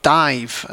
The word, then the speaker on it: Daimh: Next up, we have a Gaelic band called Dive, and it's spelled D-A-I-M-H.